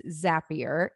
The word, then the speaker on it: Zapier: And I just flat out asked her, and she's like, it is Zapier. It rhymes with happier, and zaps make you happy.